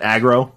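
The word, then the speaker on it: Agro: Aggro from Shadows of the Colossus? Is that how you pronounce the name? – Yeah.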